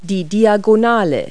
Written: diagonal

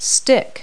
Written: stick

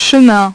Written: chemin

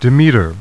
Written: Demeter

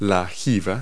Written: hump

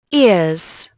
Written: ears

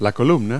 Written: column

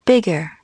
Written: bigger

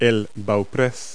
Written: bowsprit

